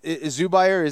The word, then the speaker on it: Zubair: That is a tough situation because again, Zubair, is that's his name? That's how you pronounce? – Yep.